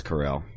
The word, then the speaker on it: Carell: Yeah, that's Carell.